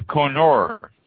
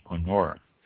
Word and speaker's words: Kerner: The Koenner, K-E-R-N-E-R, pronounced Koenner.